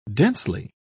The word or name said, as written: densely